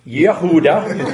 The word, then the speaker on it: Yehuda: Isn't that Judah? – Yehuda, that's right, Yehuda, Nebuchadnezzar.